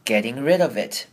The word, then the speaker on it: getting: You know, getting rid of it. 这边 getting rid of it 也要特别注意，首先是 getting 这个字呢 TT 的声音我们要念成的声音，所以会把 T 弱化变得有点像是 D 的声音，念成 getting rid of it。